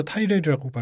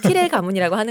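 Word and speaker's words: Tyrell: 드라마에서 타이렐이라고 발음하나요? – 티렐이라고 티렐. – 그러니까 영어 발음이 약간 애매해요. – 그렇죠. – 발레리아 발라리아처럼 티렐인지 타이렐인지 약간 애매하게 발음해요. – 왜 귀가 있어도 못 듣는 거야. – 내 말이 그 말이요. 못 듣겠어. – 그러니까. 아 이게 네바다에서는 발음 그렇게 굴리지를 않아요. – 하지만 우리는 티렐 가문이라고 하는 것으로.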